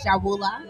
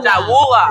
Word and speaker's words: Jahwula: My name is Jawula. – Jawula. – Jawula. Yes, J-A-H-W-U-L-A.